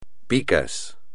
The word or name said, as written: picas